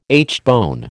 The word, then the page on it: aitchbone: American English